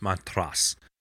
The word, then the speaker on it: Montrose: It's pronounced Montrose, but... – Correct.